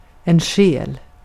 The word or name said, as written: ruh